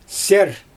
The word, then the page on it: seer: Xyzyl